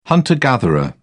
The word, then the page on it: hunter-gatherer: British English